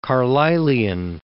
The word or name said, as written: Carlylian